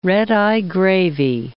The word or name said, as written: redeye gravy